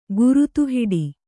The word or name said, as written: ಗರತಿಹಾಡು